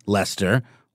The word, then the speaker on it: Leicester: But it was spelled like the name of the town in England, Leicester, which looks like it should be pronounced Leicester. That's L-E-I-C-E-S-T-E-R.